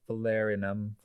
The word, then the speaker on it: Falernum: I had Falernum.